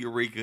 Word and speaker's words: Eureka: So I was at Ladue, and our conference, I think, was Eureka, Ladue, Eureka, UCity, Clayton. – You said it right. All of them.